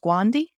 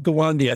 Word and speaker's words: Gawande: Is it pronounced Gwandi? – Yeah, no. Atul Gawande, I think it is.